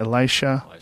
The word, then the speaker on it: Elisha: Guess what, Elisa. Uh, yeah, Elisa. How do, how do you say that? Elisha, Elisha, Elisha.